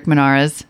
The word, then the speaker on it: Munarriz: And we're very lucky to have Rick Munarriz from the Motley Fool.